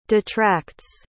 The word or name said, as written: detracts